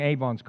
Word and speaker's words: avon: He was crushed for our, and it's not ding-dong Avon's calling, it's Avon, if you want to pronounce it correctly.